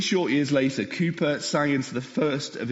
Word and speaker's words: Cowper: I know it sounds says Cowper, but I've had on good authority. Everyone else tells me it's pronounced Cowper. So it's William Cowper.